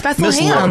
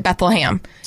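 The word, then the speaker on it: Bethlehem: that would pronounce it Beth-LUH-HAM. You have to at least admit that I'm not the only one. When I said that I pronounce it this way, you guys told me I was crazy.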